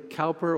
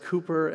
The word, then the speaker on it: Cowper: It was written by William Cowper or perhaps better pronounced Cooper.